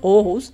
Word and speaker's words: Aarhus: Aarhus, en Dinamarca.